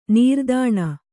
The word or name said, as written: ನಿರ್ಧನ